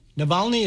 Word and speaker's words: Navalny: Navalny? Navalny? Is that how you pronounced it? – Yeah.